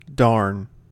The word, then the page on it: darn: American English